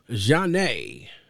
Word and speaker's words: Zhané: We're talking about none other than the debut studio album of R&B group Jah-Nay,